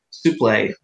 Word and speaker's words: Suplee: Did I pronounce his name wrong, right? Suplee? – Yeah, Suplee. – Suplee. – No, not Suplee. Oh, not Suplee, Suplee. – I know, I'm being sarcastic. – I forgot. – It's French. You have to pronounce it French.